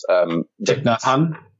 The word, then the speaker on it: Thich Nhat Hanh: Thich Nhat Hanh.